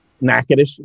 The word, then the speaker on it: Nacogdoches: Nacogdoches. It's totally not how it's spelled.